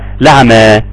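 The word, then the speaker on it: الأعمى: فهو سيدنا ورش هو اللي ترك الهمز، فزكريا بيقرأ لسيدنا ورش فيقول الاعمى، مش الاعمى، لعمى.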